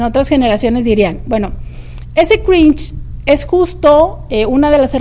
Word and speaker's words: cringe: se le ha puesto la palabra cringe. Cringe es como se pronuncia, pero se escribe cringe. C-R-I-N-G-E.